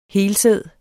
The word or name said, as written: helsæd